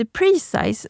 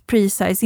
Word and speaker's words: Presize: Jag såg också en som heter Precise som jag inte har laddat hem. Den måste ju uttalas Precise, inte precis. Men den stavas P-R-E-S-I-Z-E, Precise. Och det är ett skjutmått som du kan ha i mobilen.